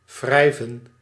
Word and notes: frotter: France; /fʁɔˈte/

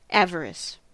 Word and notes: avarice: [ˈæv.ɚ.ɪs]; /ˈæv.əɹ.ɪs/; American English

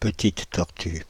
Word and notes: petite tortue: /pə.tit tɔʁ.ty/; France (Île-de-France)

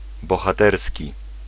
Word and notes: bohaterski: /bɔxaˈt̪ɛrs̪kʲi/

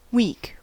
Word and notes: week: /wiːk/

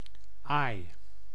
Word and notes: aai: /aːi̯/

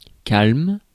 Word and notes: lithe: /laɪð/

